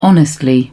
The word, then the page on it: honestly: British English